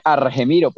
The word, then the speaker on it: Argemiro: De don Algemiro. – Que realmente se escribe Argemiro, pero le han dicho todavía Algemiro.